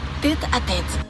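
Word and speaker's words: tête-à-tête: Es un espacio donde vamos a estar tet a tet. – Aparte hay que pronunciarlo así. – Porque teta es muy grasa.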